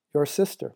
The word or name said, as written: your sister